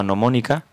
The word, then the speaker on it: gnomónica: gnomónica, con la G siempre delante, que se ha conservado desde tiempos inmemorables. No se lee, pero se escribe, ¿no?